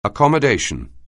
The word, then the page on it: accommodation: anglais